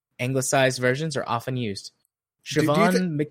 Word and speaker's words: Siobhan: This is an Irish name, and it's pronounced Siobhan.